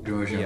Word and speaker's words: Grosjean: Grosjean.